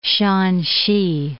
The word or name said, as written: Shanxi